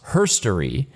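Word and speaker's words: Hxrstory: It's called herstory, but not spelled H-E-R, right? Instead of his, it's her, herstory. But it's not spelled H-E-R. It's spelled H-X-R, but pronounced herstory, which is, quote, history from a more gender inclusive perspective.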